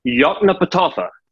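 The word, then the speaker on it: Yoknapatawpha: Yotnapatawpha.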